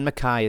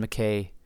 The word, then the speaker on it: Mackay: I'm not promising it's going to be the next episode, but yes, Clan Mackay is on the radar. Clan Mackay, whatever. Mackay is closer to the original Gaelic pronunciation, but I understand, especially here in the United States and maybe Canada.